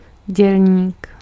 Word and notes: worker: [ˈwɝ.kɚ]; American English

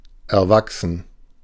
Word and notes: accrue: /əˈkɹuː/; /ə.ˈkɹu/; /ə.ˈkruː/

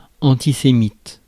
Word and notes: antisémite: France; [ɑ̃.ti.se.mit]

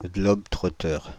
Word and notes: globe-trotteur: France (Île-de-France); /glɔb.tʁo.tœʁ/

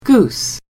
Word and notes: goose: /ɡuːs/

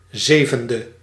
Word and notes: zevende: /ˈzeːvəndə/

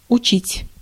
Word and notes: enseigner: France; [ɑ̃.se.ɲe]

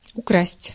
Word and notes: украсть: /ʊˈkrasʲtʲ/

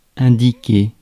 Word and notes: indiquer: France; [ɛ̃.di.ke]

